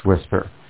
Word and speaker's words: WSPR: Structured modes that provide more robust data connection and better weak signal performance are FSK-144, JT6M, JT65, and WSPR, sometimes pronounced as whisper.